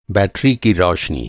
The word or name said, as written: बैटरी